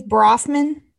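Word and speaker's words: Bronfman: As of April 2019, five people associated with NXIVM, Mack, NXIVM's co-founder, Nancy Salzman, Lauren Salzman, Seagram, Eris, Claire Bonfrim, I hope I said that right. And what was it? – Ms. Brofman. – Brofman. There we go. There we go. I see it. So for our listeners, it's Claire Brofman.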